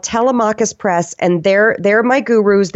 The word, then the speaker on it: Telemachus: Telemachus, T-E-L-E-M-A-C-H-U-S.